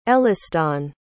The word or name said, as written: Elliston